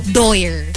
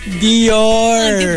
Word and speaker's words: Dior: Doyer. – Doyer? – Instead of Dior. Doyer. – Dior.